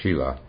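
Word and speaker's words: śīla: And then this is the third, the precepts, or in the original Sanskrit, it's called Shila, S-I-L-A, pronounced Shila.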